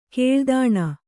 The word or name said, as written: ಕೇಳ್ದಾಣ